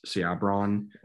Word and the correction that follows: Sebron: It's just Sebron. – Sebron. Okay.